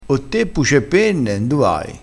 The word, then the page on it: puce e penne: dialettale